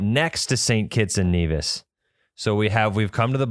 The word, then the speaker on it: Nevis: It's called Nevis. – Nevis. I don't know. We call it Nevis. It's spelled the same way as the small Minnesota town.